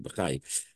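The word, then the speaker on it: Bachya: Rabbeinu Bachai, I know some people pronounce it Bachvi, but I think the Rebbe used to say the word Bachai.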